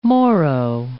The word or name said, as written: Moro